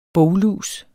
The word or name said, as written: boglus